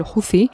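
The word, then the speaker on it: Houthi: Su líder se llama Hussein Badreddin al huzi Este, clan de los Huzis, el, que da nombre eh, al grupo, en árabe se, pronuncia Huzi, eh, los hutíes, que aquí pronunciamos en español, serían los Huthiun en árabe.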